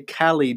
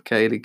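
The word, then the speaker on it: Kaley: Because some people say Callie, don't they? They say Callie Cuoco instead of Kaylee Cuoco.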